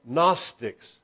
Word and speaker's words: Gnostics: Now, we spell it in English G-N-O-S-T-I-C-S. If you were looking in the Greek and you were trying to spell it as we would say an English letter, it would have an I between the G and the N, ginosko, to know.